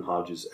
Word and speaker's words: Hodges: Everything is Jordan Hodges X.